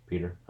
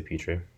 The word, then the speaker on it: Petrie: After that, Jalen Petrie. Peter? I don't know how to say his name. – You can say Petrie.